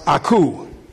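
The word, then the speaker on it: akouo: It's A-K-O-U-O. Don't get your A-K. That's A-K-O-U-O. It's pronounced aku.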